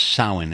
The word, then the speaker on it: Samhain: But I can tell you that it's spelled Samhain, but it's pronounced Samhain,